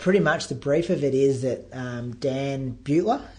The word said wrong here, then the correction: Buettner: how do you pronounce his name? – I think it's Buettner, isn't it? – Buettner. – Yeah, Buettner, that's it, yep.